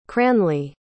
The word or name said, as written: cranley